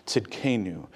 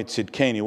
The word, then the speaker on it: Tzidkenu: That's how you pronounce that word that you see written there in your bulletin, Tzidkenu. Yahweh Tzidkenu. What it means is the Lord our righteousness.